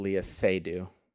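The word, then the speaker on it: Léa Seydoux: And then Lei Sudo. Am I saying her name right? – I think it's Leah Seydoux. – Seydoux, not Sudo.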